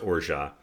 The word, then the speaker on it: orgeat: This spelled like orgeat if you see it in the store. It's pronounced orgeat.